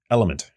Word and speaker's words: LMNT: It's spelled L-M-N-T, but pronounced Element.